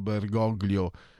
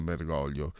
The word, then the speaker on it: Bergoglio: Signor Bergoglio, perché si pronuncia Bergoglio alla... perché è castigliano, non all'italiana Bergoglio, anche se lui è di origine piemontese.